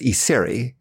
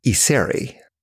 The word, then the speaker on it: Iseri: My last name is Issery. It's spelled I-S-E-R-I. – Issery. Issery? – Issery.